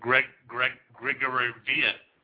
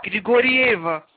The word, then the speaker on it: Grigorieva: Grigorevia. – Grigorieva.